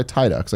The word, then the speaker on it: Tidux: Tydux, if you don't know, one of the Twitter guys, you should follow him at T-I-D-U-X. It's like Tidus from Final Fantasy X, but with an X at the end. But he goes by Tydux. I got the pronunciation for him to make sure I got it right.